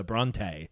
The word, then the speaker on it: Brante: We had in mind when coming up with the protagonist's name that it should sound a little French. Thus, we typically stress the very large last syllable, Brante.